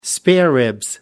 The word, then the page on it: spareribs: British English